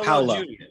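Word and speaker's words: Paulo: And you've got Andreas Kisser on lead guitar and Paolo Jr. Is that how you say it? – Yeah, Paolo. Paolo. – It's just Paolo.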